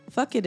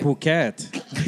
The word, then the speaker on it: Phuket: Phuket. Right? – Phuket. – You're so American. – Wow. Censors. – For the listeners out there, that was not a cuss word. It's phonetically said.